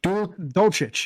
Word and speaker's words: Dulcich: Dulcich.